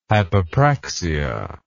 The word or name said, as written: hypopraxia